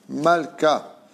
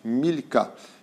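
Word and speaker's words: Malka (מלכה): Et on passe maintenant au nom de Malka. Malka qui veut dire en hébreu reine, mais qui se prononce aussi Milka, qui s'écrit exactement de la même façon.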